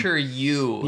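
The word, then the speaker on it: Yu (鱼): Yeah, yu. – It's like, why you, but it's... – But the way you pronounce it is... – It's in the back of your throat. Yu.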